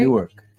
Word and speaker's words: Newark: Newark.